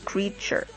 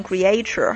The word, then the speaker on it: creature: 然而这个单词比较特殊，如果它的名词形式这个 creature，它就是不会发成这样的一个音，就没有这个 a，就直接就是这个长音 e creature。所以我发现有些中国同学会把它读成 creature 哈。就是要记住这个词的动词和名词它的读音是不同的，也是比较有趣的一个现象。